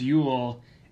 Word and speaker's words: Jul: In Norse traditions, it is called Jul, pronounced Yule, and where we get the tradition of the Yule log.